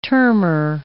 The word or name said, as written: termer